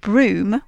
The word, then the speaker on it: Brougham: Yes, yeah, so Lady Anne Clifford is connected with two of English Heritage's most spectacular ruined castles, Brough, which is spelt B-R-O-U-G-H, and Broom, which is spelt B-R-O-U-G-H-A-M, and they're both in Cumbria, and they're both very much well worth a visit.